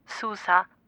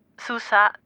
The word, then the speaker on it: Sousa: Ich bin Carolina Sousa. Sousa schreibt man S-O-U-S-A.